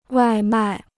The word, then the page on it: 外卖: wài mài